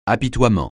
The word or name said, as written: apitoiement